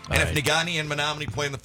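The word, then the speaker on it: Negaunee: Nugani. – why can't you pronounce words? Nigani. – That's Nigani.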